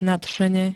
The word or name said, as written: nadšene